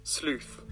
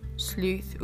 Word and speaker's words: sleuth: Let's see how it's pronounced. – Sleuth. – Sleuth. Ooh, there we go.